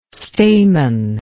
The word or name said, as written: Stayman